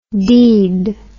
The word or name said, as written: deid